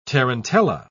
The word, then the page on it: tarantella: British English